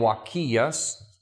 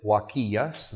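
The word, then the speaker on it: Huaquillas: So the mission trip is going to be held in Waquillas. That's the way they pronounce it. Waquillas. The double L is a Y sound.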